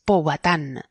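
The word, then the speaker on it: Pohuatan: Es Powhatan. P-O-H-U-A-T-A-N. Y se pronuncia así aguda.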